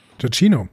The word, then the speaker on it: Giacchino: Giacchino, äh, ja.